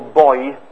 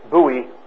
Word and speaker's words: buoy: And it's this combination of factors that will, I'll pronounce it the British way if it's okay with you, that will buoy profitable growth, buoy profitable growth.